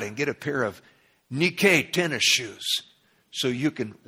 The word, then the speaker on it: nike: It's nike, spelled N-I-K-E. Does that ring a bell with anybody? We mispronounce it in the English. We call it Nike.